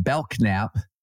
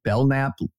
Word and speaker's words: Belknap: Same problem with Belknap and Belknap. Okay. Belknap, if you're going to pronounce it Belknap, spell it B-E-L-N-A-P, not B-E-L-K-N-A-P.